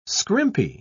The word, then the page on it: scrimpy: British English